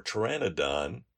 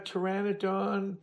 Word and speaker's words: Pteranodon: Mega Pteranodon. – P T E R. It's pter It's with oh, the silent with P, there. the silent P.